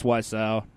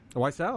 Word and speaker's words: Wiseau: Going forward, it's Wiseau. – Wiseau, okay. – It's Wiseau is how you pronounce his last name.